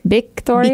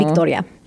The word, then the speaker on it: Victoria: Although my name, Victoria, is pronounced differently in Spanish.